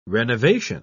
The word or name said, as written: renovation